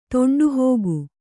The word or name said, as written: ತೊಂಡುಹೋಗು